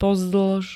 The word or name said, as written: pozdĺž